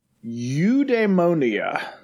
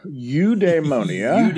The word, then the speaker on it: eudaimonia: On today's Villainous Vocabulary, I have wrangled a word, eudaimonia. Eudaimonia. Eudaimonia. Eudaimonia. It's got the demon kind of dae syllable in it.